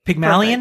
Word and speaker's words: Pygmalion: But it starts with, in ancient Greek mythology, Pygmalion, perfect.